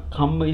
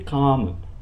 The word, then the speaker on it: kamma: Kamma is the action. Even some monks pronounce this word wrong, unfortunately. They say kamma. Kamma is the sensual gratification. Kamma, K-A-M-M-A, is the action.